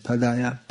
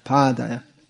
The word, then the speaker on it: Pādaya: Srila Prabhupada would correct his disciples when they said Padaya, Pādaya.